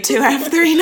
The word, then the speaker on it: POU2F3: Before we wrap up, I just want to know, how do you pronounce that POU2F3 mouse that lacked tuft cells? – Exactly like that. Oh, exactly. POU2F3 knockout.